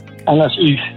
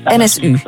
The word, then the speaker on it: NSU: De NSU Prins? – NSU, ja. – NSU.